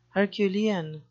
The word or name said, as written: herculean